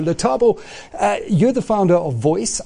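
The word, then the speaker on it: Voyc: Yes, it's pronounced VOICE, standing for Voice of Your Customer.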